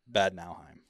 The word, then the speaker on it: Bad Nauheim: Where you were at for a couple of years, is it Nauheim? Bad Nauheim? – Bad Nauheim. – Nauheim. Okay.